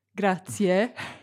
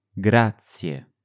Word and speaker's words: grazie: Some resources recommend pronouncing it like grazie. With two syllables at the end, C and E. But that can lead you to overpronounce the ending. When natives say it, it's a short, sharp, yeah sound.